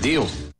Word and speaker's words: deal: He's a big deal. He's a big deal. He's a big deal. – 네, 뭐래요? – He's a big deal. – He's a big deal. 네, 발음도 deal이 아니고 D-E-O처럼 들려요. Deal.